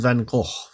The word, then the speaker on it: van Gogh: We have an artist as our next failure turned success with Vincent van Gogh. Now, I know it's said right because I just looked on YouTube for the pronunciation.